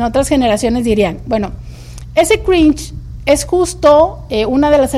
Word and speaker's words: cringe: se le ha puesto la palabra cringe. Cringe es como se pronuncia, pero se escribe cringe. CRINGE.